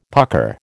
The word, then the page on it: pucker: American English